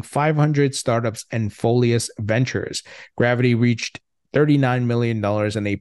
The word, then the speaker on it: GRVT: Uh, GRVT, pronounced gravity, is a crypto project building a hybrid exchange, raised $7.1 million in pre seed and seed funding.